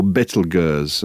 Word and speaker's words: Betelgeuse: Or Betelgeuse, as it, I think it's meant to be pronounced, which is a perfectly genuine name of a star not very far away from us.